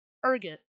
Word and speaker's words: ergot: Ergot.